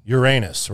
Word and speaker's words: Uranus: Uranus, right? Yes. That's how we were taught.